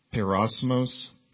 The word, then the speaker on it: peirasmos: The Greek word is, and I'll probably pronounce it, Perasmos, P-E-I-R-A-S-M-O-S.